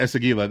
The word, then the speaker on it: Esagila: The first and most important of which is the renovation of the Esagila, which I pronounced correctly this time. So the renovation of the Esagila.